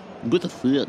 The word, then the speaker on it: Botafogo: Botafogo.